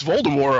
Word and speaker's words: Voldemort: And eventually somebody called me on the fact that I always pronounce Voldemort incorrectly because I pronounce the T. Not you pronounce the T. What the hell? Who the hell said that? It's Voldemort. – No, it's Voldemort, apparently. – Well, the movies, they pronounce it Voldemort. Ray Fiennes says Voldemort, so why not? – All right.